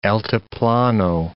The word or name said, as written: altiplano